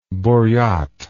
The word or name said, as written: buriat